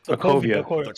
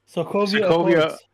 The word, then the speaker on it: Sokovia: Sokovia. – Sokovia.